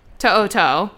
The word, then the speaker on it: To'o: How do I say it? – I think it's To'o.